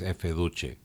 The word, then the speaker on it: Efe Duce: my contacts there have confirmed that the brand name is indeed intended to be pronounced in Italian as Efe Duce.